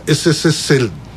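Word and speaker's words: SSSL (СССР): 略称は、はい、アルファベットですよ、あの英語読みで言いますよ、 CCCP です。はい、ただしロシア語読みになるとこれは SSSL ですね、うん、 SSSL です。 – へえ、 C は S と読みます、 P は L と読みます。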